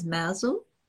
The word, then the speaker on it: Mousehole: And one place that totally captivated me was a little place, and it's pronounced locally as Mousel, but it's spelt Mousehole.